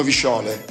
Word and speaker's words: visciole: Come lo dico? A Milano dicono visciole. – Visciole. – Cosa diciamo? Visciole, ok, con l'accento.